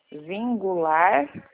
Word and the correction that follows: Singulare: Singulare. É como se estivesse escrevendo Singular. – singular. Isso, singular, só que daí coloca o E no final.